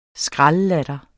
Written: skraldlatter

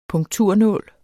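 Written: punkturnål

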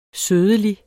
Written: sødeligt

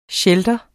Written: shelter

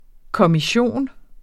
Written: kommission

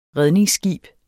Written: redningsskib

